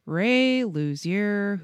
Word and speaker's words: Luzier: Ray Luzier, whose name I'm probably mispronouncing. – I think you're saying it right.